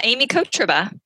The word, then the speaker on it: Aimee Kotrba: Aimee Kotrba.